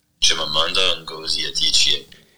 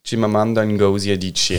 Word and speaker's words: Chimamanda Ngozi Adichie: Chimamanda Ngozi Adichie. Chimamanda Ngozi Adichie.